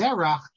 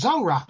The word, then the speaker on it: Zorach: I see, by the way, so David says in Hebrew Wikipedia, its name is Zerach, but people would pronounce it Zorach.